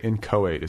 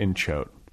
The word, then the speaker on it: inchoate: Like I look at the file and it's just like 100 pages of just, and by the way, inchoate is how you pronounce that. It's not inchoate.